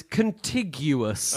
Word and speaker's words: contiguous: Correct pronunciation contiguous. Only. – Contiguous. – And that's Tom's guess is contiguous is the correct pronunciation.